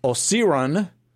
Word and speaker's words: Osiren: His band Osiren, which I pronounce Osiren. It's Osiren, like mm-hmm. the powers of darkness.